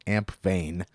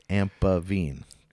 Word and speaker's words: Ampevene: ¶¶ All right, so that was Remethiou Ampeven, and I pronounced the name wrong earlier, you know, because I'd only ever seen it in writing, so I was pronouncing it Ampevene, but it's Ampevene, correct? – That's it.